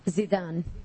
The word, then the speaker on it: Zeidan: Zidane. – Zidane? – Yeah, Z-E-I-D-A-N.